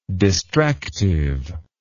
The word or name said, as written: distractively